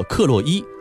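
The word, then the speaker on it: Károly: 那卡尔是一个德语发音，匈牙利语呢叫做克洛伊，所以呢他作为匈牙利国王的时候称之为克洛伊四世。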